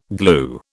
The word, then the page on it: glue: British English